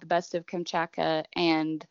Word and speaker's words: Kamchatka: How do you say it? Kachachma or? – Kamchatka.